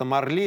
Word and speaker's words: Марли: То есть ударение все-таки на последний слог, естественно. Ну и Марли стоит посетить хотя бы ради того,